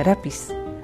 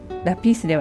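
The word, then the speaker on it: lápiz: それから、先週出てきたラピス、これもラピースではなくてラピスなので A の上にアクセントをつけます。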